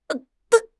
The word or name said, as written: dun4